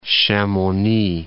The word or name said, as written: Chamonix